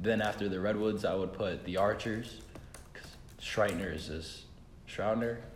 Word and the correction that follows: Schreiber: I can't say his name. So filthy. So filthy. I'm sorry. That man is disgusting. – Wait, from what team? – The Archers. Tom Schreiber. – Tom Schreiber.